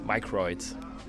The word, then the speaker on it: Microids: Wie spricht man Microids aus? Und ich wollte ihn schon fragen, ob er jetzt Microids sagt. Aber er spricht von der Firma wirklich mal als Microids.